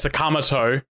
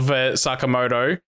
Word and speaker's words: Sakamoto: It's not that they can't pick for Sakamoto, it's that they've said it wrong. They're going as a Kamato instead of Sakamoto.